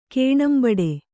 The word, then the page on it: ಕೇಣಂಬಡೆ: Kannada